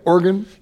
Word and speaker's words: Oregon: Oregon? – You did say Oregon right, yeah.